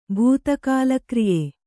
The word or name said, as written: ಭೂತಕಾಲಕ್ರಿಯೆ